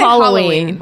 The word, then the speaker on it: Halloween: You guys say, I say Halloween. – Halloween. – Halloween. – You say it like Halloween. Hollow, and we say it like hallow. – Yes. – Like there's, as in it's All Hallows Eve.